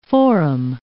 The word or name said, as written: foram